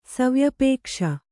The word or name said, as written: ಸವ್ಯಪೇಕ್ಷ